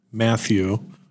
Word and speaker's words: Matthew: Matthew, or Matt, that's M-A-T-T-H-E-W, Collins, C-O-L-L-I-N-S.